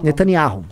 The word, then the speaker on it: Netanyahu: Netanyahu.